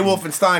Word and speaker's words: Wolffenstein: I say Wolfenstein because it almost rhymes with Mordecai.